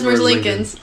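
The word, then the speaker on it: Merzlikins: Elvis Merzlikens.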